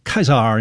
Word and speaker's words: Caesar: You know, one vowel combination you see more often in Latin is A-E. Like in Caesar, you know, it's pronounced Caesar in classical Latin,